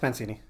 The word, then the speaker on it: Mancini: Yeah, it's Mancini. – Mancini.